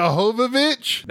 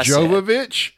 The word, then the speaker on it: Jovovich: Mia Jovovich? – That's what I Jovovich? – Said. – Jovovich? – Jovovich. Jovovich. – I don't know. It's a soft J.